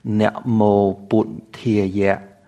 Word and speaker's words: namo Buddhaya: Let's continue with that same style, but we'll add the Khmer pronunciation, just so you can feel what it's like to chant in a different pronunciation of Pali. So instead of namo Buddhaya, we'll try namo Buddhaya, namo Buddhaya.